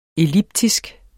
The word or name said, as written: elliptisk